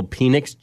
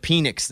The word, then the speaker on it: Penix: Is it Penix or Penix? – Yeah. I think it's Penix. – Is it? – The fact that you just went with Penix, though.